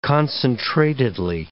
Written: concentratedly

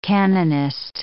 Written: canonist

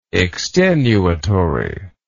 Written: extenuatingly

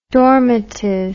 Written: dormitive

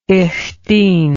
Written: ich dien

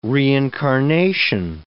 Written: reincarnation

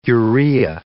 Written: urea

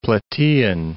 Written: Plataean

